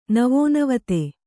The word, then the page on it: ನವೋನವತೆ: navonavate